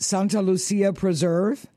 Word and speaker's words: Santa Lucia Preserve: Is it Santa Lucia Preserve? – Yeah, that's right, in California.